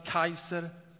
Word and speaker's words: Caesar: Caesar på latin uttalas kaiser.